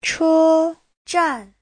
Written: che1zhan4

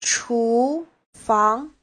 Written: chu2fang2

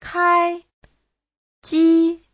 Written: kai1ji1

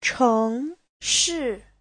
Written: cheng2shi4

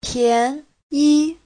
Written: pian2yi5

